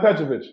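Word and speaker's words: Petrović: I mean, I just think of like Petrovic, not Petrovic. Did I say his name right? – Yeah, Drazen Petrovic.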